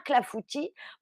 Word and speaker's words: clafoutis: Regardez, je vous remontre un clafoutis, C-L-A-F. Comme ça se prononce avec un S qui ne se prononce pas à la fin, évidemment.